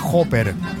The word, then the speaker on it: Hopper: Bueno, se llama Hopper. Se escribe con H. H-O-P-P-E-R.